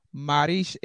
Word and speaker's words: Marrakesh: And Philippe, you can remember back to your first trips to, I'm going to butcher this, so please correct me, Marrakesh.